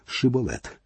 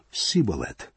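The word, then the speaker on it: шибболет: І казали йому: скажи-но Шиболет. А той сказав: Сиболет, бо не міг вимовити так.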